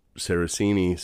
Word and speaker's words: Saracini: is how you would say it, but I grew up Saracini, so I didn't know he was talking to me at the time.